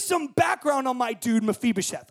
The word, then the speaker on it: Mephibosheth: Mephibosheth. Oh, it's like class. Like I say it, you say Spanish class. Okay, repeat after me. Mephibosheth.